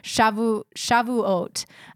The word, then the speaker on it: Shavuot: I looked up the pronunciation. – Oh, good. I'm glad you did. – And I'm probably still butchering it. Shavuot is what I heard.